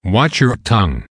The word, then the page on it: watch your tongue: American English